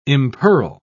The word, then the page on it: impearl: British English